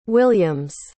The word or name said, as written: williams